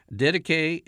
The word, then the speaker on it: Didache: Didache is spelled D-I-D-A-C-H-E.